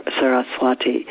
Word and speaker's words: Saraswati: And the goddess Saraswati, who's one of my favorite goddesses, her name is sometimes pronounced, as Chris was saying to me, Saraswati.